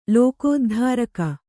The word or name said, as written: ಲೋಕೋದ್ಧಾರಕ